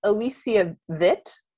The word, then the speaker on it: Alicia Witt: The next one, I think her name is actually pronounced this way. I think it's Alicia Witt.